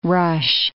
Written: rush

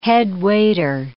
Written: headwaiter

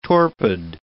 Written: torpid

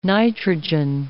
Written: nitrogen

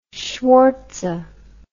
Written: schwartze

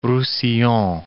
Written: Roussillon

